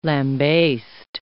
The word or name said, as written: lambaste